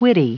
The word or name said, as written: witty